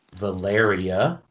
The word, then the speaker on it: Valeria: So that gets you into cool things like Valeria, right?